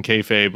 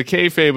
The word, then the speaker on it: kayfabe: kayfabe, which I've mispronounced for years. I appreciated your etymology of it rhymes with hey babe in the book.